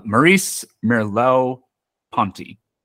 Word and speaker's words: Maurice Merleau-Ponty: So the first philosopher that we can talk about is, I'm not gonna pronounce his name right. There's no way, but Maurice Merleau-Ponty. – No, you got it.